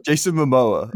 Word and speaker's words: Momoa: Jason Momoa.